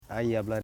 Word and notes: aijabla: ai.ja.bla

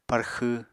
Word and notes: parxw: Apatani

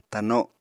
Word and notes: tano: Apatani

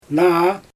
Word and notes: naa: Xyzyl

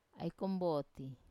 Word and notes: ai komboti: Wamesa

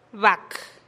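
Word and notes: bak: Teotitlán del Valle Zapotec

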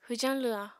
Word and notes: hwjañ lwha: apatani

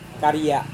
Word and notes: karia: Serewen

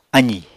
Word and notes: azì: apatani

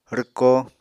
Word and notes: rwko: Apatani

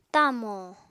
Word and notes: tamò: apatani

